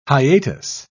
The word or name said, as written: hiatus